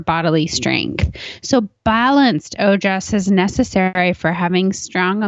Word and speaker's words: ojas: And ojas is spelled O-J-A-S.